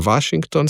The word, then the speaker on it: Washington: Washington z dvojnim V-jem, SH, NG, ne, čeprav je K izgovorjen, in imamo potem Washington, seveda pa sem izgovoril zobno-ustnični V pred samoglasnikom, ker je to sistemsko pravilo za slovenščino in seveda ta ton sem ohranil zaradi sklanjanja.